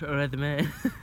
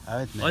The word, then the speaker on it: Redmayne: Eddie Redmayne. – It's pronounced Redmayne. – Eddie Redmayne.